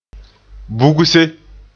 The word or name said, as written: bugse